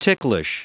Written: ticklish

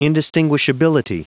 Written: indistinguishability

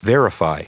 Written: verify

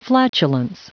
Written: flatulence

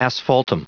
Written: asphaltum

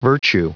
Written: virtue

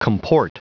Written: comport

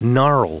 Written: gnarl

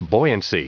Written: buoyancy

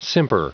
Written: simper

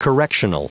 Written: correctional

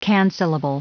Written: cancellable

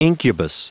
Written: incubus

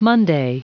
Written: monday

